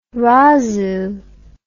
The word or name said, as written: razoo